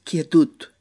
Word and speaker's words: quietud: O quietud se escribe igual, quietud, pero en catalán es quietud.